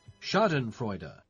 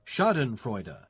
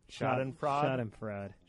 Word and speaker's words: Schadenfreude: But I will tell you, for a long time, I pronounced that. I would stop in the middle. I'd be like, Schadenfreude.